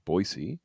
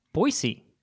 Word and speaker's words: Boise: Can I just pull back the curtain for one moment and note your apparently very correct pronunciation of Boise. – Boise.